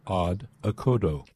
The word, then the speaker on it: Odd Okodo: Odd is simply O-double-D, and then O-K-O-double-D-O, Odd Okodo. It's a duo consisting of German electronic experimental drummer Sven Kasirek, that's K-A-C-I-R-E-K,